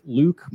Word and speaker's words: Louque: You can find me at Jake Luke. My last name is spelled Louque.